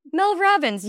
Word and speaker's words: Mel Robbins: Mel Robbins. You Mel know Robbins. That. Yeah, you said it right.